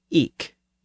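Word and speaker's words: ik: Well, in parts of eastern England, especially around Norfolk, people pronounced that word with a hard K sound at the end. So they said eek, usually spelled I-K.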